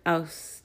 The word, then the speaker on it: Au: In Budge's Egyptian Hieroglyphic Dictionary, page 36a, we find the word aus. Au is pronounced as the French eau, o, water, which denotes the third person singular female.